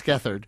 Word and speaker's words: Gethard: Chris Gethard, who I think, you know, I was reading from my script before, and I think I just stated the two syllables of his name separately. So that could be, you know, somebody could riff on that themselves. But it's Gethard.